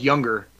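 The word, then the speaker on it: Junger: It's pronounced Younger, but it's spelled with a J, so it's J-U-N-G-E-R, but it's Sebastian Younger, and he was the author of a book called War, which was a companion piece to the documentaries Restrepo and Korngal.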